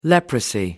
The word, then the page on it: leprosy: American English